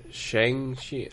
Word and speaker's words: Shang-Chi: Even Shang-Chi, like... I don't even know if I'm pronouncing that right. Right? Is it Shang-Chi? Shang-Chi? – It's Shang-Chi.